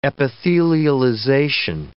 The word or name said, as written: epithelialization